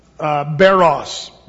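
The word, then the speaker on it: baros (βάρος): Fortion, P-H-O-R-T-I-O-N, if you want to spell it phonetically. Fortion. Or the other word is baros, B-A-R-O-S.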